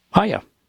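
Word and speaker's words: hayah: Hiya.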